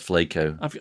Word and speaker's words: Flaco: Is it Flacco or Flaco? – I see. You're saying my pronunciation is wrong. – Oh, I've got no idea. What did you call him? – No, I called him Flacco, but I think you're probably right, it's Flaco.